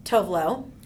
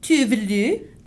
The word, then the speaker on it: Tove Lo: I mean, so at the beginning of this podcast, we played a little of one of my favorite artists, Tove Lo. – Tove Lo.